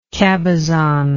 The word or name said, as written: cabezon